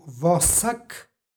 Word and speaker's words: vosak: Nepostojano a izgovorili smo i u obliku vosak prema ekvivalentu u srpskom i prema pravilu o gomilanju suglasnika. Ovde je reč o ekvivalentu u srp, dodaje nepostojano a.